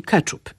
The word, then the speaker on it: ketchup: Tak, nazwa ta już, można powiedzieć, dawno temu się spolszczyła i wymawiamy ją współcześnie wyłącznie po polsku, czyli ketchup.